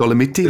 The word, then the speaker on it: Dolomites: I think in the Giro, being in the, how do you say it in English? Dolomites, I think. – The Dolomites, like, the Dolomiti, the Dolomites, yeah.